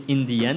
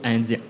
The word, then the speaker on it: NDN: En anglais, on dit Indian, donc c'est les lettres NDN qui, quand on les prononce, font Indian ou Indien, quand on les prononce dans ce sens-là.